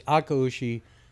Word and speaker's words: Akaushi: Uh, the smash burger, burger was uh, Akaushi, um, Akuashi. yeah. yeah. See, I, well, because I used to say it wrong all the time, and I was yeah, but but it's Akaushi um,